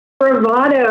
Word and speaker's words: Spravato: Spravato, I know it's a neat name, and actually it's spelled S-P-R-A-V-A-T-O.